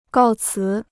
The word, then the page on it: 告辞: gào cí